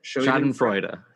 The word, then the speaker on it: Schadenfreude: Schadenfreude? Is that how you say it? – Schadenfreude.